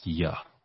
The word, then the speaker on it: Yod: Yod is always pronounced Ya.